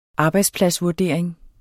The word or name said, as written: arbejdspladsvurdering